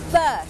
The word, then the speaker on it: berth: berth berth berth อ่านออกเสียงเหมือนกันกับคำว่า birth birth